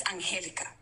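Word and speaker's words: Angélica: And yes, my name is Angelica. It has an accent on the E, and it is extremely disrespectful.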